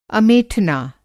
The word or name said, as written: अमेठना